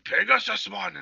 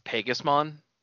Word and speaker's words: Pegasmon: And then I was like, Pegasusmon! And I was like, oh, shit. And then he's, like, already, like, on him. He's like, whoa! – I guess in Japanese it's shorter. It's just Pegasmon.